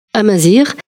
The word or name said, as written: amezri